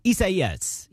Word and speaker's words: Isaias: Am I saying it right? Close enough? – Yes. Yeah, close enough. Isaias. Yes. All right. That is correct.